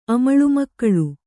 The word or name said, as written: ಅಮಳುಮಕ್ಕಳು